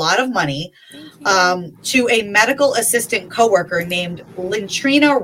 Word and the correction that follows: Lenitra: Ross. So I have a picture also Lenitra. I'm sorry, I think I was saying that wrong. Lenitra Ross.